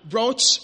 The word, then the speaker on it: brooch: They're even secured by some sort of a golden brooch somewhere here. A brooch. You pronounce that? Brooch. I mean, am I correct? Is it brooch? Brooch. Okay. Brooch here.